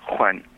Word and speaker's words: Juan: So, like, for example, the Spanish name Juan, when pronounced appropriately or correctly, is Juan, like that, with a nice velar fricative at the beginning.